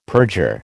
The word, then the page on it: perjure: American English